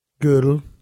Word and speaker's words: Gödel: There was the academic homeroom for the likes of Albert Einstein, Oppenheimer, and Kurt Gödel. Is that how you say his name? – I don't know. – There's an umlaut over the O. – Gödel. – Okay.